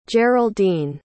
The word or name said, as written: geraldene